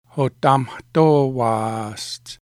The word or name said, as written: Hotamahtoevaaestse